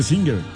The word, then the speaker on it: Singer: Y la pronunciación oficial debía ser Singer. – Sí, claro. – Sí. Sí, es que sí era. Sí. Pero le decíamos Singer siempre, sí, en los, sí, compañeritos.